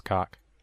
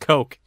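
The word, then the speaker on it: Koch: I bet you it's actually pronounced cock. – It's not. – I bet it is. – It's really not. – I bet it's pronounced cock. – It's really... – It's pronounced coke.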